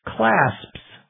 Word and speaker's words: clasps: For example, there's a word C-L-A-S. P-S, the plural of clasp, when pronounced carefully, is clasps. Sps. SPS. However, when pronounced normally in normal conversation, it would probably sound something more like class. I have a bunch of clasps I need to put on this coat. The P almost disappears in more normal, rapid pronunciation.